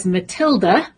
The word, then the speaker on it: MTLD: The here I go with another acronym, MTLD, affectionately referred to as Matilda, and that's the consonants being pronounced, nothing to do with waltzing Matilda or my accent.